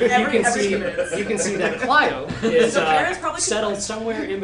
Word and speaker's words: Clio: It's actually pronounced Clio.